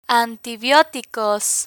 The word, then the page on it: antibioticos: spanish